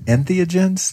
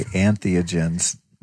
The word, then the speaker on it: entheogens: Is it entheogens? – Entheogens. – Oh, I always say antheogens.